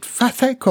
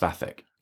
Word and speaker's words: Vathek: Now you've read, is it Vathek or Vatek? Or I, have I've always heard it pronounced Vathek.